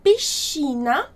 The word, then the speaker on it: piscina: Então, esse SC juntos vai ter esse som como, quase como se fosse o nosso X mesmo, né? Então, piscina vai ser a pronúncia correta do S e do C.